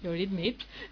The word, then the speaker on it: Eurythmics: Eurythmics, Sweet Dreams.